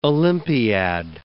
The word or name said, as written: Olympiad